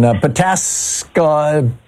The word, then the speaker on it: Pataskala: Pataskala? Oh, hi. Say it. Tell me how to say this. – Pataskala.